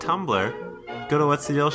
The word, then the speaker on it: Tumblr: It's pronounced Tumblr.